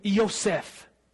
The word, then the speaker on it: Yosef: He had given it to Yosef, Jewish pronunciation of Joseph. That's my name, pretty cool. Yosef.